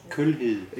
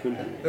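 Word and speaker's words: Kjulhild: That's in Danish, Kulhild. – Yeah, see, I can't say it properly. – K-J-U-L. – Yes, yeah. – That's it. – Kulhild.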